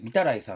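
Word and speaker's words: 御手洗: 例えば、例えばがちょっとレアなのしか思いつかない、お手洗いと書いて三たらいさんとか言うじゃないですか。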